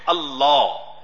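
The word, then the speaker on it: Allah (الله): So this second alif is hidden. Grammatically it is there. We pronounce it Allah, but we don't write it.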